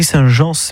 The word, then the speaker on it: Saint-Jean: Ça s'écrit Saint-Jean, mais ça se prononce Saint-Jean. – Et oui, à la Provençale, bien sûr.